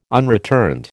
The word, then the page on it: unreturned: American English